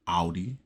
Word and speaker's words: Audi: It's Porsche, not Porsche, you know, and it's Audi, not Audi.